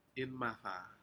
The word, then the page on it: inmada: Aneityum